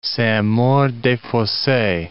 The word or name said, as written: Saint-Maur-des-Fosses